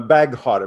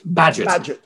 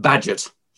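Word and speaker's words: Bagehot: Badgett. It's a very, very difficult word to pronounce. Very difficult for British people, and impossible for anybody who's not British. But Badgett.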